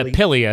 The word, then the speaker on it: Pili: is it Pili nuts? Is that how they say it? Pili? – Yeah. Pili as in like the Philippines. Pili?